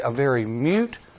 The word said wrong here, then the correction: moot: Did I say it right? Moot. Thank you. I will learn that word yet.